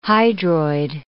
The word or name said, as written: hydroid